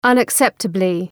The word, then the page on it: unacceptably: ,ʌnæk’septəblı